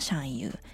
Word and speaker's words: xiangyu: はい、えー、本日のゲストのシャンユーさんは 2018 年 9 月からライブ活動を開始しました日本の女性ソロアーティストです、えー、読み方はシャンユー